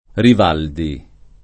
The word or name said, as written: Rivaldi